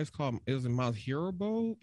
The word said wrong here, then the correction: Mount Horeb: H-O-R-E-B. Is how it's pronounced. – H-O-R-B-B. – Corp? H-O-R-E-B. It's in Wisconsin, and it's called, it's a swamp. – Mount Horeb. – Okay, Mount Horeb. Mount Horeb, Wisconsin.